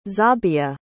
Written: zabia

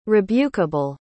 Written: rebukeable

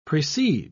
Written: precede

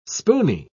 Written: spoony

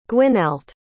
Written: gwynallt

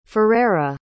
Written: furaira

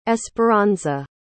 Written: esperanza

